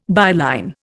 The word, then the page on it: byline: Indian